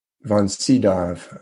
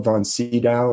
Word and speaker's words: Sydow: um, it's it's spelled von Sydow or von Sydow. S y d o w.